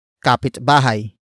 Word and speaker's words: kapitbahay: And that actor is kapitbahay. Kapitbahay. Kapitbahay. Kapitbahay is spelled as K-A-P-I-T-B-A-H-A-Y.